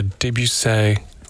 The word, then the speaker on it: Debussy: Who understands the weight of a Beethoven symphony and the nuance of a Debussy prelude.